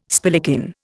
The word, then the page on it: spillikin: Indian